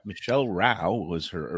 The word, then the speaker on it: Rowe: It's spelled row, like row your boat, R O W E, but pronounced Rao, as in the British word for fighting.